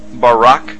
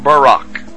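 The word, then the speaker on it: barak (ברק): the Hebrew word is barak. So lightning, or a flash of light, in Hebrew is pronounced barak or barak.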